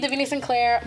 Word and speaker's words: Davina Sinclair: Honestly, it's spelled how it sounds. The Davina Sinclair on Instagram and TikTok.